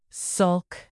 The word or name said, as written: sulk